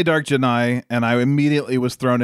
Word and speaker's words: Jedi: Jedi. It's like the Jedi. It's like Jedi with an N.